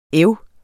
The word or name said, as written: æv